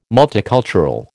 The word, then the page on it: multicultural: American English